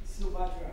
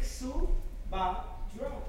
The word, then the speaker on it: Subhadra: Let's break that down in syllables. – You can call me Sandra, but it's No. I wanna call you that. – Just think Subhadra.